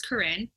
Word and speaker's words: Corinne: Well, right off the bat, my name is Corinne. – Corinne. – It's spelled like Karen.